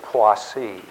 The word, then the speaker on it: Poissy: The next particular piece I want to address is the, basically what is happening is the colloquy of Poissy is looming. Okay, that's spelled P-O-I-S-S-Y.